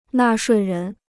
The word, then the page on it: 纳税人: nà shuì rén